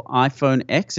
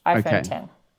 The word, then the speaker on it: iPhone X: I believe it's Apple iPhone X. Is that correct? – It's spelled X, but it's actually pronounced 10. So it's like the Roman numeral is X. So it's iPhone 10.